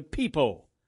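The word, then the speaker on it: pepo: While the word watermelon refers to both the fruit and the plant to botanists, the plant is a peepo.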